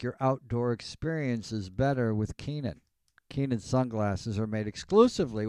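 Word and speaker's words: Kaenon: That's K-A-E-N-O-N, Cast15. Keenan, clearly better.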